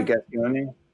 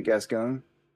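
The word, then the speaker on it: Gascón: But it's like Garcone or Garcone. – Oh, yeah, Gascone. Yeah, Gascone. – Gascone.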